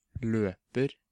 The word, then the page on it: løper: substantiv; Norwegian